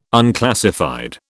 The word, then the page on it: unclassified: British English